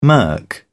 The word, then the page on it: murk: British English